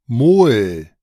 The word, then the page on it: Mol: German